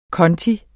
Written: KONTOEN

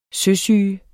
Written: søsyge

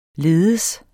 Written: ledes

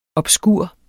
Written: obskur

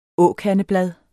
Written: åkandeblad

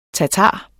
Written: tatar

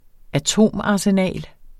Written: atomarsenal

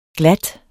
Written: GLATTESTE

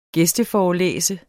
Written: gæsteforelæse

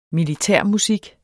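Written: MILITÆRMUSIK

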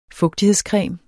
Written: FUGTIGHEDSCREMEN

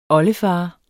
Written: oldefar

